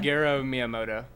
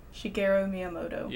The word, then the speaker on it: Shigeru Miyamoto: Shigeru Miyamoto. – Shigeru Miyamoto.